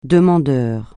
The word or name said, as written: demandeur